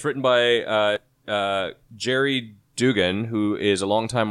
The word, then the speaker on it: Gerry: It's spelled G-E-R-R-Y. – It's Jerry. – Jerry. – It's a soft G.